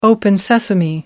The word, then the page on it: open sesame: American English